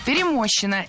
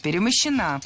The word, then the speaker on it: перемощена: Перемощена – это неверно. Правильный вариант – перемощена. Ударение в этой форме падает на окончание «а» – перемощена.